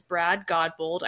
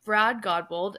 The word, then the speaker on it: Brad Godbold: Today, my guest is Brad Godbold.